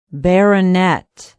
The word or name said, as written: barronett